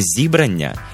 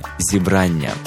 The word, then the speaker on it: зібрання: Прошу запам'ятати правильний наголос: не зібрання і не зібрання, як можна часто почути, а саме зібрання. Наголос на другому складі з української на українську і навпаки.